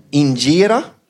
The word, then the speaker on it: Injera: Injera. Heter det, va? – Mm. – Bra uttal.